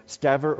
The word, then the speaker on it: Stever: And the context, hear what Stever, if I don't know if I pronounced the last name right, but it's S-T-E-V-E-R, Stever or Stever.